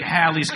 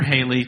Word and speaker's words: Halley's: I mean, I was like Halley's Comet, really. Or Haley, depending on how you want to pronounce that.